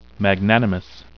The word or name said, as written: magnanimous